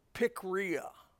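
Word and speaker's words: pikria (πικρία): The word bitterness, in the Greek, this is the pronunciation of the Greek word, pikria. Pikria. Picky. We get our picky. That's the definition of bitterness. Pikria.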